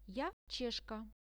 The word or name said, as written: ja cheshka